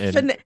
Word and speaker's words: Candace: It's Candace. It's Kansas with a D instead of an S after the N.